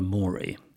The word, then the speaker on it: mori: Well, I think he would have said Dulce et decorum est pro patria mori. And mori has to rhyme with glory, of course.